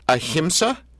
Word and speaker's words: ahimsa: So, ahimsa.